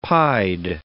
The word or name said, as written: pied